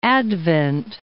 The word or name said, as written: Advent Bay